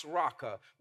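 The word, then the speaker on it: raka (ῥακά): Now, when he says whoever insults, this word insult in the original language is pronounced raka, which means empty headed or worthless.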